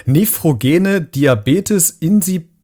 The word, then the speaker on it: Nephrogene Diabetes insipidus: Nephrogene Diabetes insipidus. Pidus, ist das richtig, was ich gerade gesagt habe? – Ja, das ist richtig